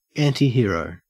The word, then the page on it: antihero: Australian English